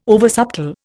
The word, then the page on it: oversubtle: Indian